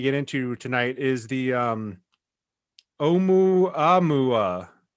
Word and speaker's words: Oumuamua: Oumuamua. I just read on the NASA site that it's pronounced Oumuamua. Oumuamua.